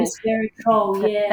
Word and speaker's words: resveratrol: Resveratrol, thank you very much.